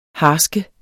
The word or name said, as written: harsk